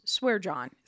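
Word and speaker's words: Schwierjohn: It's pronounced Swear John. Throws a lot of people off.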